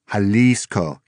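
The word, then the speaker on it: Xalisco: Jalisco, he said, pronouncing the word. Don't confuse it with a state in Mexico pronounced the same way but spelled with a J. The state of Jalisco is one of Mexico's largest, and Guadalajara is its capital. This town, he said, spells its name with an X.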